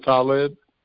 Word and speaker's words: Talib: My first name is Talib. – Hi, help me out with that, brother. How you spell that? – T-A-L-I-B as in boy. – T-A-L-I. Okay, Talib. Okay, like Talib Kweli.